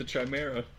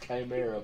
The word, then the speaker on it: Chimera: Wait till you meet the Chimera. – Pronounced Chimera, but go on.